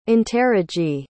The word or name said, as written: interrogee